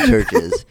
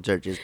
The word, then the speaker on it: Chvrches: It's just called Churches. But Chvrches? – The band's name is just called Churches, but it's spelled with like V's.